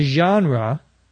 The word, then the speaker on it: genre: A genre, G-E-N-R-E, is a French word. We pronounce it genre in English, which means a specific type or style of literature or of movies or of music.